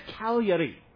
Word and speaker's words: Cagliari: And we were about to fly to Cagliari. All right. It's spelled almost like Calgary in Canada, but they pronounce it totally different.